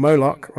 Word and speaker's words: Moloch: Moloch rhymes with Bolocks.